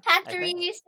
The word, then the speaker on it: Pachirisu: Pachirisu? – Pachirisu? Pachirisu.